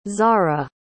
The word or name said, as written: zarah